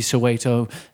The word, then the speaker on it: Soweto: Because, I mean, to someone from the outside, you see Soweto and you think of, am I even pronouncing it correctly? – Yeah. – I am. – So where do you accept you? – Yes, nailed it. Perfect.